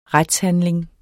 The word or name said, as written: retshandling